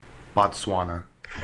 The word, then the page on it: Bostwana: English